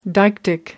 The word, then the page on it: deictic: American English